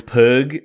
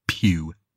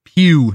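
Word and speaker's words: Pugh: I believe it's Pew. – Pug? – Pew. – Pugy? – Like Pepe Le Pew.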